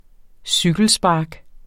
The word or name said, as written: cykelspark